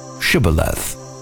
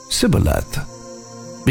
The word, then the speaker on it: Shibboleth: If he answered no, they would tell him, "Say the word Shibboleth." If the fugitive would say Sibboleth, because he couldn't pronounce the word correctly, they would grab him and kill him at the shallow crossings of the Jordan River.